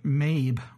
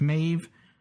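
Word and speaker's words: Maebh: Now, it's spelled Mabe with a B, but it's apparently pronounced Maeve with a V. It's kind of hard to tell in the episode itself, though. It's spelled M-A-E-B-H, B as in boy, but evidently it's pronounced V as in Vincent.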